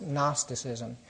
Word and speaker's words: Gnosticism: It's spelled with a G, but it's pronounced Gnosticism.